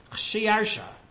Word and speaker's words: Khshiarsha: Khshiarsha. The Greeks could not properly record his name because they didn't have a letter to represent the shin sound.